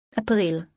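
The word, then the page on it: April: Greek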